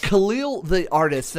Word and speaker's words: Khalil The Artist: Khalil The Artist, did I pronounce that correctly? – Yeah.